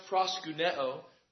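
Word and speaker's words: proskuneo (προσκυνέω): It's pronounced proskuneo.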